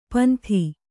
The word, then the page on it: ಪಿಂದು: Kannada